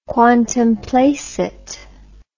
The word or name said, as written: quantum placet